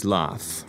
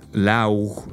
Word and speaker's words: laugh: But you see, the end of the word is a simple f sound, right? Laugh. Laugh. And it sounds like the end of the word photograph.